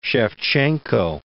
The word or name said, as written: Sevcenko